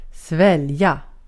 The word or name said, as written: svälja